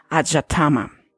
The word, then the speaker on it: adhyatma: deletrear y es una A D H Y A T M A es como se pronuncia como Ajatama y se deriva de dos palabras que es Adi y Atman, Atmahama.